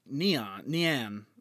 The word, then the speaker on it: Nian: And its name is Nian. N-I-A-N is how it's said.